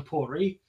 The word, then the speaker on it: Port Ruighe: I figure I'd go back to Telescope and try the Port Ruighe, or, you know, people call it Port Ruighe, whatever, but being Gaelic, you don't pronounce half the letters that are in the word, so it's actually Port Ruighe.